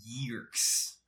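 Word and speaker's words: Yeerks: Yeah, it has two E's. Y-E-E-R-K-S. Yerks. – That's... – I'm assuming, like, if it's Yerks and has a silent E, what is the point of that? – It's alien. In the audiobook, they call them Yerks. – Yeah. – So that's how it's pronounced, I guess.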